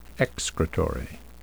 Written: excretory

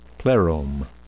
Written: plerome

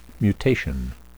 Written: mutation

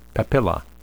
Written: papilla

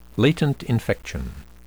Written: latent infection

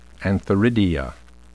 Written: antheridia

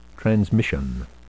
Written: transmission

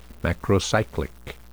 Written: macrocyclic

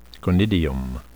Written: conidium

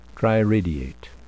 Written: triradiate

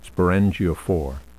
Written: sporangiophore

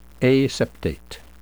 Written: aseptate

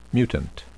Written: mutant

